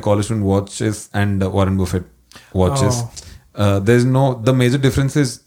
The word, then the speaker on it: Buffett: Warren Buffet or yeah. Warren Buffett? – No, Buffett, yeah. – Warren Buffett. – Yeah, I used to say Buffet.